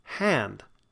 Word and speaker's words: hand: And this is the sound that we get in cat and bat and hand. Now, hand is one that we typically don't pronounce correctly. We go to say it and we sort of say hand, hand. Ah, hand.